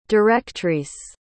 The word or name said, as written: directrice